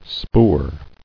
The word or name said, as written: Spoor